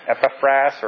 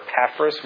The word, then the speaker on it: Epaphras: Epaphras or Epaphras, we could go either way with that pronunciation.